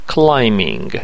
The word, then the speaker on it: climbing: Really، I am not interested in rock climbing، بی تلفظ نمیشه، climbing، علاقه به صخره نوردی ندارم.